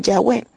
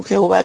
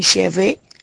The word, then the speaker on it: Yahweh: Los que esperan en Jehová, o los que esperan en Yahweh. Yo por lo regular utilizo la pronunciación Yahweh. No me gusta la pronunciación Jehová que usa la Reina Valera, porque sé que es bastante incorrecta.